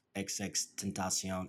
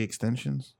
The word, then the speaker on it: XXXTentacion: Rest in peace to XXXTentacion. XXXTentacion. – Is that how you pronounce it? – I think it. – Yeah. It's not supposed to be extensions.